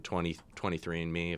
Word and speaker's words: 23andMe: You know, you mentioned 23andMe, if I'm saying that right.